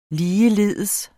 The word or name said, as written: ligeledes